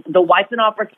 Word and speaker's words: Weitzenhoffer: The Weitzenhoffer School of Musical Theater at the University of Oklahoma.